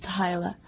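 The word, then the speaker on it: t'hy'la: a Vulcan word, which is usually pronounced Tyla, which can mean either friend, brother, or lover.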